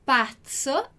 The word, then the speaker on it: pazzo: É muito igual a palavra razzo que a gente viu, mas a pronúncia é diferente, né? A gente vai dizer pazzo e razzo. Uma com o som da bexiguinha esvaziando e a outra com o som da abelhinha, ok?